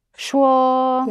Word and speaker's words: 说: Shuo.